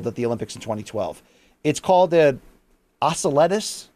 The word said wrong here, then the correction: Oseledets: I'm pronouncing that problem, butchering it probably. Oseledets.